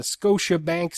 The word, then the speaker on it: Scotiabank: If I watch a hockey game at the Scotiabank Saddle Dome, I'm no more likely to bank with Scotia. Do you think I said that right? – I think you did.